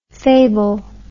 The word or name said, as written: faible